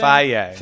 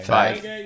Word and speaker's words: Feige: Feige, is that how you pronounce that thing? – Feige. – Feige. – Like the yogurt.